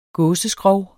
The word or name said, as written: gåseskrog